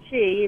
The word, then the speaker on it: Qi: Qi is energy in Chinese. – Qi, it's spelled Qi, and sucker is sucker.